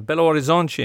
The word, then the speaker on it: Belo Horizonte: Uh, they took on, on June 29th, an amateur USA side in uh, Belo Horizonte in Minas Gerais.